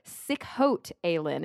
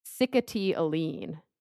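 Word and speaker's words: Sikhote-Alin: Oh, sickhote alien is how I thought you pronounced it, but mm-hmm. That's totally not right at all. It has like this weird pronunciation that, oh. Sickety Aline.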